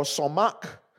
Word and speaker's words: samach: You know, the Hebrew word for joy is spelled S-A-M-A-C-H or somach.